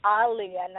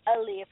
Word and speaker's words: Aaliyah: And her name is pronounced Aaliyah, not Aaliyah for you people. She said it plenty of times. It's pronounced Aaliyah, so make sure y'all get that.